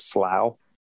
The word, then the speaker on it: slough: Is it slough? It uh, is.